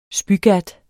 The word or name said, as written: spygat